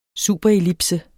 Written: superellipse

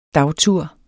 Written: dagtur

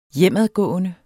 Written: hjemadgående

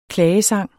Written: klagesang

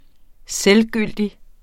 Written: selvgyldig

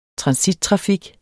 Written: transittrafik

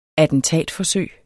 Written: attentatforsøg

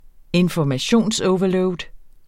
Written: informationsoverload

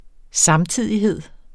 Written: samtidighed

